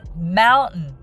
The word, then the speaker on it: mountain: その他、冒頭でもお伝えした山は mountain と書きますけれども、えー、 t の音を抜いて mountain mountain というふうに言います。